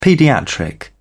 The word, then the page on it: paediatric: British English